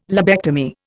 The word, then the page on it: lobectomy: Indian